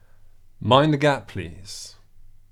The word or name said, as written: mind the gap please